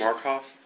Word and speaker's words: Markoff: Markoff, M-A-R-K-O-F-F.